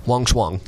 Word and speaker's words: Wang Shuang: And speaking of which, we had a recent new signing, uh, Wang Shuang. Am I saying that right? – Um, Wang Shuang, yeah, Wang Shuang, uh, out of out of China, uh, mm-hmm.